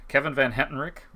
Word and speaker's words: Kevin Van Hentenryck: I'm going to butcher the last name. I'm sorry. Uh, Hentenryck. Kevin Van Hentenryck. – Maybe that I didn't. Sounds pretty good.